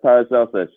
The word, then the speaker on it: Paracelsus: Yeah, it's Paracelsus. It's like the degrees Celsius, but without the I.